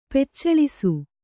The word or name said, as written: ಪೆಚ್ಚಳಿಸು